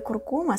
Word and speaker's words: куркума: И ударение в слове «куркума» ставится именно на вторую «у», как бы странно это ни звучало.